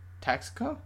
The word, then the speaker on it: Tosco: Tosco. – Costco? – Tosco. – With a T.